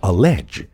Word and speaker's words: allege: 那我们不妨啊，再来学一个和词根 LEG 表示说话相关的单词，叫做 allege， A-L-L-E-G-E，动词断言、指控。 AL 做前缀和后面的 L 形成了辅音双写啊，然后结尾加一个不发音的 E，这个词表示声称一件事情是怎样的，但是还没有被证实，所以经常在法庭上指控某人犯罪的时候出现。